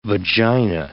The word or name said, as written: vagina